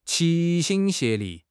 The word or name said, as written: 齐心协力